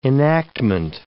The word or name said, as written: enactment